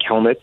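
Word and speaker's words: Kelenic: First of all, I heard Michael Kay saying at the game last night that his name is actually pronounced Jared Kelnick, the second E is silent, apparently.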